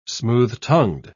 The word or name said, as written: smooth-tongued